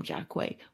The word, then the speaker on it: Jaquai: So yeah, she follows me at Jaquai. Jaquai underscore Jaquai, because again, I like for people to pronounce it right. You know what I mean? – Yeah. – Because, you know, Jackie is different from Jaquai, but shout out to all the Jackies out there.